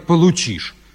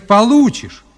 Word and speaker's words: получишь: И если верно сократишь, то пятерку получишь. И если верно сократишь, то пятерку получишь. – Николай Владимирович, нельзя говорить «получишь». Надо говорить «получишь». Вы неправильное ударение ставите.